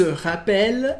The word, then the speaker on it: rappelle: Elles se rappellent, elles se lembram. Lembrando que a pronúncia de rappelle termina no l.